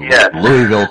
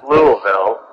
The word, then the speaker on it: Louisville: Louisville, Kentucky. – And well pronounced, brother. It's Louisville.